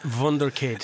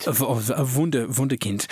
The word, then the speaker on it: Wunderkind: And I'm looking at Adeyemi going, okay, 19-year-old wonder kid. He's... – It's pronounced wunderkid. – Wunderkind.